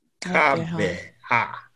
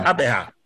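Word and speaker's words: Gqeberha: So, okay, like you only to actually hear how to say it. So it's no longer Port Elizabeth. It's now Xebega. – Xebega. Oh, it's easy. – Yeah. – Xebega.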